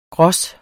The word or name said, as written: gros